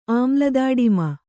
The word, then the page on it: ಆಮ್ಲದಾಡಿಮ: āmladāḍima